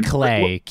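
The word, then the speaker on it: Klei: Yeah, Clay. K-L-E-I, I think. – Yeah, is that how you pronounce it? Yeah, Clay Entertainment and Blitworks, I believe.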